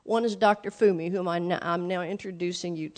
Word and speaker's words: Funmi: But her name is spelled Funmi. So if you were just an American looking at her name, you'd think it's Funmi, okay? Funmi, F-U-N, right? But the N is... That's getting a little detailed for your information. I'm sorry. But so anyway, you pronounce her name Fumi, Dr. Fumi.